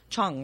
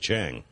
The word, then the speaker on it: Chang: It's actually spelled Chang, but it's pronounced Chong, right? – Oh, correct. I've been saying Chang.